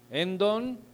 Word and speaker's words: endon: Endon así como lo escucha, endon, con acento en la E, endon, que quiere decir dentro, y teos, que quiere decir Dios.